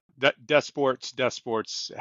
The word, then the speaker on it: DesPortes: Close enough. Yeah, it's French, but I'm not. Desports, however you want to say it, it's fine, Patrick.